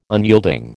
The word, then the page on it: unyielding: American English